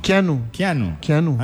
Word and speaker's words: Keanu: ¿Cómo se pronunciará? Kino. – Keanu. – Ah, como Keanu Reeves. – Como Keanu Reeves, pero es Keanu Chacón.